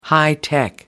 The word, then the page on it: hi-tech: British English